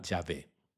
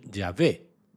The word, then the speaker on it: Yahvé: Y no sabemos exactamente cómo se pronunciaba. Pensamos que se pronunciaba Yahvé.